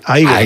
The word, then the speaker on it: Igor: Y bueno, toda la parafernalia eléctrica en torno a la creación del monstruo, el gran personaje secundario, Igor, pronúnciese Igor.